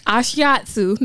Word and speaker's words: Ashiatsu: I did get certified in Ashiatsu though. – What's Nashiatsu? That's mushrooms? – Okay, very much Ashiatsu. That's how you say it.